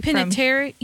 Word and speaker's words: Panettiere: Or Hayden, I'm not going to say this right, Panettiere. – Panettiere, yes.